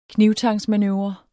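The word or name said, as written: knibtangsmanøvre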